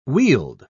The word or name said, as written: wield